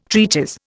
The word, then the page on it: treatise: Indian